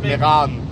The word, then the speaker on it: Meran: Und Timmelsjoch war zu, also sind wir wieder runter nach Meran. Dort erstmal im Stau gestanden. – Meran. Zweite Silbe ist lang. Merano, ja, eben. Meran.